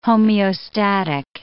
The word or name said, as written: homeostatic